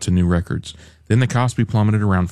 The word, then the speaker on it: KOSPI: The benchmark KOSPI, how do you say this, Randy? – KOSPI.